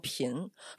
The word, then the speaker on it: 一瓶: 就是我跟你们说啊，就是书桓喊一瓶啊，就是有一个技巧，就是前面那个一字是半个音阶，然后平不要念成平，要念成频，否则它就叫一平。